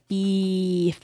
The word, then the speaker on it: Beeef: There's three E's in that band name, so you have to pronounce it Beef.